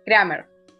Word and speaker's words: Kramer: que quiero además aclarar que fuera de aire aprendí a pronunciar su apellido, así que voy a decirlo. 3, 2, 1. Kramer. ¿Está bien? Haceme señas. Sí, está bien, está bien.